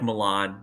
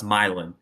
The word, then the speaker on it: Milan: It's spelled like Milan, like the Italian city, but it's pronounced Milan.